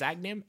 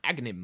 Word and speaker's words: Agahnim: I really should have practiced pronouncing these, but I think it's Agnim. A-G-A-H-N-I-M. Agnim.